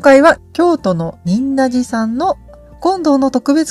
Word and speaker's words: 仁和寺: 仁和寺と、呼ばれていますけれども、と仁弁に二と書いて仁と読む漢字ですね。その仁という漢字に平和の和と書いて仁和寺さんです。